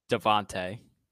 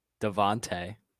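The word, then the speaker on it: DeVonta: Devon Smith. I know him. – Devontae. Get the name right. – Devon. – Devontae.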